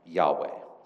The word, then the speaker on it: Yahweh: But today, right, scholars believe that the tetragrammaton, YHWH, was most likely pronounced Yahweh.